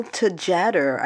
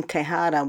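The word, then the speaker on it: Tjader: It's from 1965 from an album called Soul Sauce, which is from the most successful non-Latino Latino musician named Cal Tejada, I think. I almost pronounced his name Tejada, which would have been like a Latin pronunciation.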